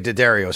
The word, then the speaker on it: Daddario: And by the way, Daddario is how you say it, Matthew Daddario.